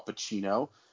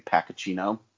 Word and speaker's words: Pacino: So finally, our final nominee then is Al Pacino. By the way, side note, up until I was like 12, I thought you pronounced his name Pacacino.